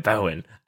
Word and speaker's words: Bowen: You know, Bowen. It's a Celtic name, apparently, and then it also works phonetically in Mandarin, Chinese, and so my parents just kind of chose something that worked on both.